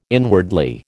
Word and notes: inwardly: American English

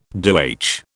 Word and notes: DoH: British English

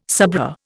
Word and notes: sabreur: Indian

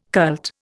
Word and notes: cult: Indian

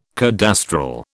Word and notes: cadastral: British English

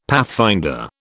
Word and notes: pathfinder: British English

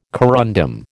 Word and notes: corundum: American English

